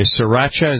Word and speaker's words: Sriracha: Sriracha, is that what it is? – It is.